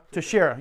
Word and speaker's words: Teixeira: How do you pronounce his name? – Teixeira. – Yeah, that's it.